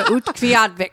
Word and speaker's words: Utqiagvik: There's not enough clothing in the world for me to put on to go to Utqiagvik.